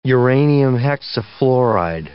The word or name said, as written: uranium hexafluoride